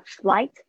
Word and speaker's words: flight: OK，注意一下 GH 是没有发音的 flight number。